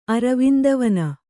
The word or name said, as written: ಅರವಿಂದವನ